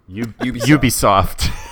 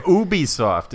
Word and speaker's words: Ubisoft: Ubisoft. – There you go. – Now I'm thinking software. – Ubisoft. It's not that. – It's not ubiquitous software.